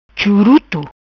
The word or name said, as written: Churuto